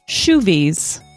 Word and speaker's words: shoovies: Here we go, round two, shoovies, which rhymes with movies.